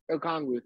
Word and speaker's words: Okongwu: You know, you still got Inekwu, or however you say his name, the Auburn kid, right? You still Okongwu? Got AJ Okongwu, thank you.